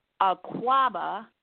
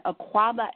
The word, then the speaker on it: Akwaaba: Akwaba, The Awakening. And Akwaba is spelled A-K-W-A-A-B-A, and it's The Awakening.